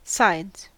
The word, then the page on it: sides: American English